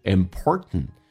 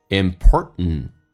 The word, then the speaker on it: important: important，而且第一个 t 可以不发音。我们经常会听到老外这么去说 important， important，你可以发也可以不发，但很多老外选择不发。